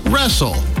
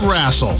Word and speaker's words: wrestle: Also, it's pronounced wrestle, not wrassle.